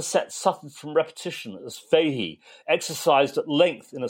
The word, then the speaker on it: Fahey: It's a live review of John Fahey. Is that how you pronounce it? – Well, I would say Fahey, but it's quite hard.